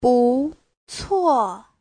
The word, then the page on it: 不错: bu2cuo4